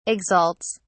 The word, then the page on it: exalts: British English